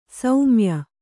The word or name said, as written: ಸ್ಮಯ